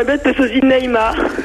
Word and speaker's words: Neymar: Eh ouais, quand on connaît le football, on prononce Neymar, carrément.